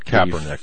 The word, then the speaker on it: Kaepernick: Kaepernick. 49ers. – Kaepernick.